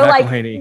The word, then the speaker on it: McElhaney: So McElhaney.